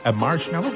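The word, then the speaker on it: Marshmello: La preuve que vous êtes au meilleur de la musique, c'est Marshmallow, comme ça on le prononce.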